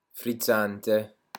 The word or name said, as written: frizzante